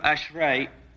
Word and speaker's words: ashrei: Now this word, translated blessed, pronounced in Hebrew ashrei, is one of the more difficult words we have to translate.